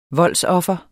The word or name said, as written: voldsoffer